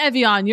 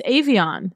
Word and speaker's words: Evian: It's not Evian. You're right. It's Evian.